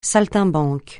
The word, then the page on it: saltimbanque: francais